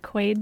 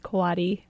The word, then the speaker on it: Quade: And one thing I'll say right off the bat is my name looks like Quaid, but it's actually pronounced Quaddy.